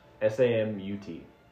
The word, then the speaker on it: Samut: Alright, so, Samet, or... – Samet, there we go. Samet. – Samut. – Yes. – Samut. – Which is how you should pronounce it, not how it's spelled.